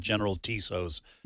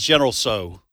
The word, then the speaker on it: General Tso's: My wife mocked me when I used to pronounce it General Tso's. That's how it's spelled. – Good reason. – TSO. It's General So.